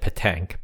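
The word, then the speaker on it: pétanque: Pentique sounds French, so it sounds like their variation of it that they would be good at. Petanque. And then Cambodia? – Sorry if I mispronounced it. Petanque. P-E-T-A-N-Q-U-E.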